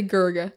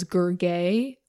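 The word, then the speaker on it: Gurgeh: But then later it comes up that he's annoyed that it's being mispronounced as Gurge or Gurgi.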